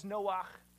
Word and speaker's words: Noach: And there's one small difference in how you pronounce the two names. One is Noah, one is Noach.